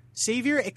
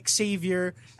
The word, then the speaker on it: Xavier: There are multiple pronunciations for the word Xavier. Xavier, Xavier, and Xavier are all acceptable.